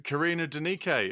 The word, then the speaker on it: Karina Denike: We are here with Karina Denike.